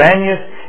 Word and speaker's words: Banias: Banias is the Arabic pronunciation of Panias, which draws its name from the false god it was built to honor, Pan, the god of fear.